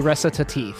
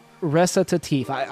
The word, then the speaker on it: Recitatif: So I looked it up. It allegedly recitatif. – Well, what a hana who? – Recitatif. – Let me try it. Recitatif.